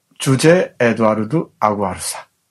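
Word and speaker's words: José Eduardo Agualusa: 주제 에드와르드 아구아르사. 이름이 참 어렵습니다. 아, 조지 에드워드 아구아르사. 이 정도 하면 될 것 같은데 포르투갈 발음이어서 그렇습니다.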